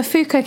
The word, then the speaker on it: Foucault: We ought to spell out Foucault. It's Foucault. – Yeah. Wait, how are you... Am I saying it right then? – Yeah, Foucault. It's French.